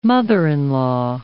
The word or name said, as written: mother-in-law